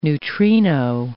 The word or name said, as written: neutrino